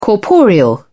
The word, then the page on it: corporeal: British English